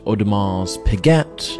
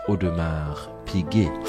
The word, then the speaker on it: Audemars Piguet: Third, we have Audemars Piguet, never to be pronounced Audemars Piguet, Audemars Piguet. It is Audemars Piguet.